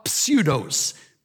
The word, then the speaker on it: pseudos: The lie is in Greek, ta pseudos, P-S-E-U-D-O-S. We get, obviously, our word pseudo from it. In Greek, you pronounce the P. In English, it's silent.